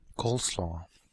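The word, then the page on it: coleslaw: Canadian English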